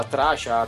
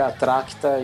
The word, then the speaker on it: Attrachta: Tem gente que fala errado, fala tracha, trasta, tá não sei o que, mas o, o, a pronúncia correta, do jeito que tá escrito, é atracta e, e, e, essa é a dona atracta lá que, que cuidava dos doentinhos.